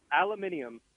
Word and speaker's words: aluminium: All right, aluminium. – Aluminum. – Yep, that's how they pronounce it, aluminium.